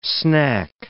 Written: snack

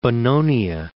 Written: Bononia

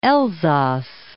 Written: Elsass

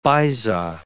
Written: baiza

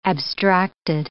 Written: abstracted

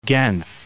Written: Genf

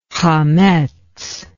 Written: hametz

